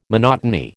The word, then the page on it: monotony: American English